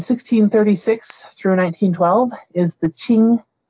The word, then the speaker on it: Qing: And Qing is also spelled with a Q, so it's Q-I-N-G. So just like the Qin we had earlier, they're a slightly different tone. You don't really have to say the tone in English, but it's the Qin and the Qing. The Qing dynasty, you can think of Qing rhymes with Ming, but we say it's smiling again.